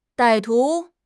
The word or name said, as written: 歹徒